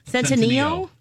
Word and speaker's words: Centineo: Centineo? – Noah Centineo.